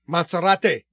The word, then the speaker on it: Monserrate: The name of the company was Montserrat, but... – Monserrate. – But it wasn't necessarily in... – Bogota? – Bogota. It was just the name of the company. Mm-hmm. So, anyway. – Is that how you say it there? Monserrate. – La montaña de Serrate, sí.